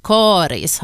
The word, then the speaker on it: Karis: Rekommendationen om långt av Karis har funnits sedan början av 1960-talet, och för det så fanns det faktiskt inte uttalsrekommendationer överhuvudtaget. Samtidigt är uttalet med korta troligen äldre, ofta är det talspråkliga uttalet äldre än det officiella.